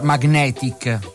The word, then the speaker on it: Magnetic: Sai che si pronuncia Magnetic? – No, è la prima volta che la sento. – Vabbè, l'inglese non c'è la gne, non c'è la gne.